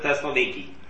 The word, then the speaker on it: Thessaloniki: Oops, Thessalonica. It's not Nike, I'm sorry. – Yeah, no, you can say that. In Greek, they pronounce it Thessaloniki. That's how it's pronounced.